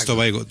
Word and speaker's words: Tobago: De hecho, Tobago es como ellos pronuncian tabaco. – Ajá, o sea, la segunda isla se llama Tabaco. Vale. Los dos nombres serían españoles, Trinidad y Tabaco. Pero es Tobago, Tobago, dicen ellos.